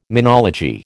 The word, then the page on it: menology: American English